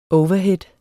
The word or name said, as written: overhead